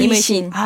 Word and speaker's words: 임의신: 근데 이거 잘 읽어야 되겠네요. 그렇죠. 임의신입니다. – 네. 임의신. – 아, 네. 발음이 약간 어렵네요. 외국인한테는.